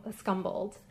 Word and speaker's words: scumbled: The description upstairs says that he used scrumbled paint. I don't know if I pronounced it. Scumbled. – Scumbled? – Yeah.